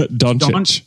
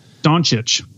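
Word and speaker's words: Dončić: it's Doncic. Doncic.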